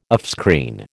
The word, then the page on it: offscreen: American English